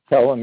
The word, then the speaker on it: Pelham: Pelham, yeah.